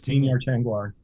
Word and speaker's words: Vinyar Tengwar: And by the way, I think, where can we get Tingwar, am I saying it right or am I getting it backwards? – Vinyar Tengwar, VT.